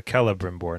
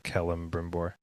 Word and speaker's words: Celebrimbor: It's Celebrimbor, not Celebrimbor. – Oh, Celebrimbor. Okay.